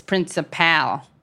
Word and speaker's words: principal: I do remember him saying, remember, the last syllable of principal is principal. Because he was the principal. So yeah. – He was showing that he's a pal.